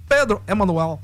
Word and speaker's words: Emanuel: É o caso do Vasco, que estreia o técnico português Pedro Emanuel. E do Grêmio, que se reforçou. – Perdão, como que pronuncia? – Pedro Emanuel. – Emanuel. É tipo aquela música, né? Do Manuel.